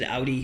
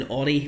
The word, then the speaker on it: Audi: You pronounced it properly when you said Audi, which is what they say in Germany, whereas it's an Audi where I come from.